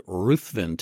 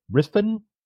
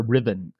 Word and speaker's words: Ruthven: And I understand the correct pronunciation is Riffin, Riffin, really? – Or Riven. And I still slip and say Ruthven a lot, too, because I read it as with a U in it, as everyone does.